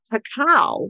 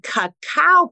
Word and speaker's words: cacao: Let's talk about the difference between cocoa, which is spelled C-O-C-O-A, versus cacao, which is spelled C-A-C-A-O. And I'm going to say them this way slowly so that you'll be able to differentiate the two as I share them with you.